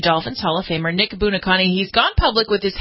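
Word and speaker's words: Buoniconti: The Hall of Famer, Nick, how do you pronounce it? Buonaconte, is that how you pronounce it? – Yeah, Buonaconte.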